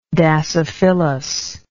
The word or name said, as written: dasyphyllous